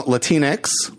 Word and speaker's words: Latinx: And as somebody who is Latino, Latinx rhymes with Kleenex.